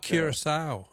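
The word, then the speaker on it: Curaçao: And I don't know if I'm pronouncing it right, but I thought it was Curacao. – Curacao, yeah. No, that's very good.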